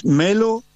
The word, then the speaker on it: Melo: Es Melo y Kinsikeu. se escribe, Quintriqueo. Se escribe de una forma y sí. se pronuncia de otra. – Bueno, Lucas, nosotros somos Melo con una sola L.